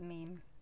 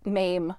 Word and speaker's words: meme: I will never forget that meme. – I know you won't. Mm-hmm. You do speech to text, and it will pronounce it as MAME. – MAME! Like yeah. MAMERY. – Yeah. It's like, it's meme.